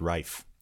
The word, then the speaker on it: Ralph: He's Welsh because that's why his name's meant to be pronounced Rafe.